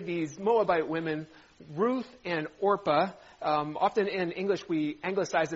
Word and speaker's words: Orpah: These women's names are Ruth and, mispronounce it, Orpah. And if I say Oprah today, this is a little known fact, but Oprah Winfrey, her birth name was Orpah.